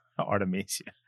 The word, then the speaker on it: Artemisia: Artemisia? – Oh, yeah, Artemisia.